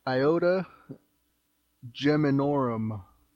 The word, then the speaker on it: Iota Geminorum: That would be Iota Geminorum.